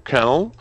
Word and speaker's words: Colonel: Марка ЗИЛ возродится в виде нового полноприводного внедорожника, но в США его создадут внедорожник полковник, который будет называться Colonel. Как-то так, по-моему, читается это слово. Пишется. Colonel. И, соответственно, как-то да, Colonel там они L это теряют.